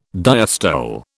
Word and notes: diastole: British English

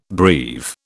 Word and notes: breve: British English

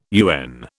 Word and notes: UN: British English

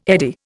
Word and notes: eddy: Indian

